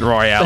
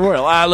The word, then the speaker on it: Royal: You're going to have text messages about not pronouncing it Royale, by the way. – No, it's Battle Royal. Look, it's wrestling. It's Royal. It's different.